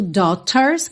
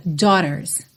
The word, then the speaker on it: daughters: O G-H não vai ter som nenhum, e esse T tanto pode ser falado como um T mesmo, daughters, que fica mais britânico, ou na pronúncia americana a gente pode usar um rarara, daughters, daughters, ok?